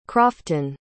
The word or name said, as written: croften